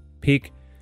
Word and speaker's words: peak: Now, the word peak should be pronounced with a longer E, because I often hear it with a short E, like peak, peak. And it sounds like pick, almost, like to choose, all right? So in order not to confuse the two, just give it time. Give that E more time. Say it longer.